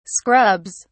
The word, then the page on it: scrubs: British English